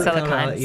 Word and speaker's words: Silicon: I always thought it right. Silicon Valley. – Silicon. – Yeah. Say it wrong. Silicone goes somewhere else.